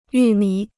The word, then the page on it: 玉米: yù mǐ